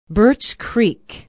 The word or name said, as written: birch creek